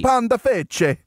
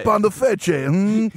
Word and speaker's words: Pandafeche: I'm sure I pronounced that wrong, but Panda, Pandafesh, F-E-C-H-E. – Pandafesh. It's Italy. You got to say it, you know. Sure. Pandafeche, it goes good with some nice gabagoo, you know.